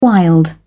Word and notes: wild: British English

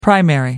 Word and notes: primary: American English

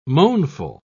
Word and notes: moanful: British English

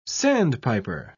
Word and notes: sandpiper: British English

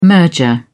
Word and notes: merger: British English

